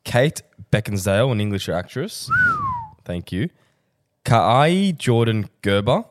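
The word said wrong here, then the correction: Kaia Gerber: How would you say it? – Kaya Gerber. – Kaya. Yeah, that's 100% yeah, that right. Good. Yeah, I stuffed that.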